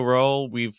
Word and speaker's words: Rowell: Rainbow Rowell, am I pronouncing her last name right? – I think it's Rowell. – Rowell. See, J.K. Rowling.